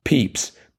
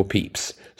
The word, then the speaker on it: Pepys: Strangely, Pepys, which is spelt, by the way, P-E-P-Y-S, is actually pronounced Pepys, P-E-E-P-S. And on the episode which Randall is referring to, I had said, I think, Pepys.